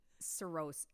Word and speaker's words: xerosis: Is called Cirrhosis. It's spelled X-E-R-O-S-I-S. – Cirrhosis and not psoriasis.